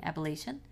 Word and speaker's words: Appalachian: I keep saying that wrong. – Appalachian. – Thank you. I said it right.